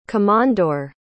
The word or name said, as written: komondor